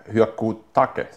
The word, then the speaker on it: Hyakutake: Och 1998 kom den här Hyakutake, heter den. – Hyakutake? – Ja. Enligt klassisk japansk uttal.